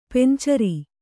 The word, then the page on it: ಪಂಚಾರ: kannada